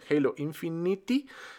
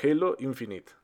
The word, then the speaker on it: Halo Infinite: Si ¿Sí lo dije bien, Halo Infinite, Halo Infinity. No, Halo Infinite.